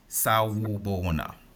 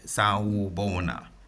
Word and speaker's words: Sawubona: In my language, when we greet someone for the first time, we say, Sawubona. I'll spell that. It's S-A-W-U. Yeah, S-A-W-U. Sawubona. B-O-N-A. One word. Sawubona.